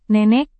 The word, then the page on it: nenek: Indonesian